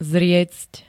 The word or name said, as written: zriecť